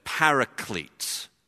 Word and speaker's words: paraclete: Well, the word behind that is the word that, if you were pronouncing in English, you would say was paraclete.